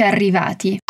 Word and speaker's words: arrivati: Un'altra volta, osserva bene la pronuncia delle doppie C in eccoci e la doppia R in arrivati. Ah, eccoci arrivati.